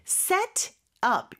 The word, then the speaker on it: set up: 자, 오늘의 문장에서 가장 중요한 발음 포인트는 set up 이 부분인 것 같아요. 연음이 되면서 set up. Set up, T 발음이 리을처럼 됩니다.